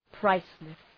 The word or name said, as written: priceless